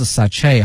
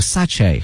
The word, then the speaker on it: sachet: 它的发音有两个，一个是 sachet，还有一个 sachet 哈，一个英式的一个美式的都可以，但是呢有一点后面这个 T 是不能发音的哈，表示那种比较小的一些小袋子。